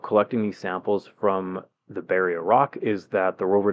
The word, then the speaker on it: Berea: These samples that they collected on March the 30th, these were taken from a rock that has been named Beria. I believe that's how you say it, B-E-R-E-A.